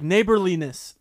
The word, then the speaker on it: neighborliness: Neighboriness? – No, it's like neighborliness. Neighborliness. – I think you're stressing the wrong syllable.